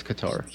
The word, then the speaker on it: Qatar: Qatar? – It's Qatar. Qatar. – Whatever. – With Qatar, that's Qatar. – Yeah. Okay, Qatar. It's shit, Steven. – Qatar. – It's like guitar, but with a K.